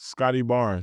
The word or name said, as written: Scottie Barnes